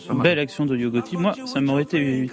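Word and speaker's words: Yo Gotti: Yougoti, A Boogie With Dawdy et Ty Dolla Sign, ce sont les trois artistes et ils signent avec Like That.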